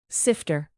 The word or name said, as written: sifter